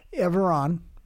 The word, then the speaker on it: Everon: Everon, right. Rhymes with Chevron.